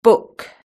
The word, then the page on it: book: British English